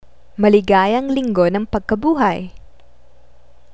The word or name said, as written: Maligayang Linggo ng Pagkabuhay